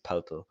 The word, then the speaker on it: Poeltl: And I went and watched a German interview, well, Austrian TV interview, and that's how they pronounce it, Poeltl.